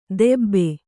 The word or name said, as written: ದೆಬ್ಬೆ